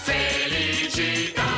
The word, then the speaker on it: Felicità: Felicità, l'accento sulla A. – Ma sì, buttiamola così. Si sa. Un'ora passa e va. Non so se faccio bene o no. Vabbè, facciamo finta che. – Felicità.